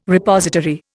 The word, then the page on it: Repository: Indian